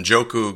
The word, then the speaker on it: Njoku: OJ Howard going 15th, and Njoku going. Uh, I just I think that's the first time I pronounced his name correctly.